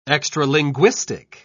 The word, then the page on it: extralinguistic: British English